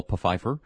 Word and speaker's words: Pfeiffer: Michelle Pfeiffer. – Mm-hmm. – I pronounce the P in Pfeiffer.